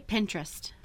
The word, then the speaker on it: Pinterest: Is it Pinterest or Pinterest? – We say Pinterest.